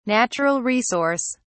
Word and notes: natural resource: British English

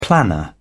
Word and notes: planner: British English

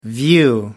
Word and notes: view: American English

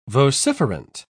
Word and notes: vociferant: British English

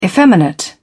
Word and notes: effeminate: British English

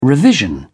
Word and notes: revision: British English

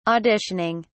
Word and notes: auditioning: British English

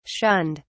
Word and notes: shunned: British English

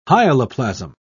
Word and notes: hyaloplasm: British English

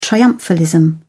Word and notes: triumphalism: British English

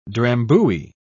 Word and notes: drambuie: British English

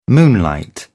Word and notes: moonlight: British English

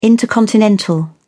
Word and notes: intercontinental: British English

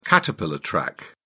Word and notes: caterpillar track: British English

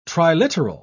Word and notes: triliteral: British English

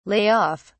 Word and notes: lay off: British English

